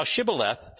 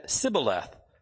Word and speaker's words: shibboleth: And if he say, nay, then said they unto them, say now Shibboleth. And he said, Sibboleth, for he cannot frame to pronounce it right.